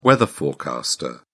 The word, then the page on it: weather forecaster: British English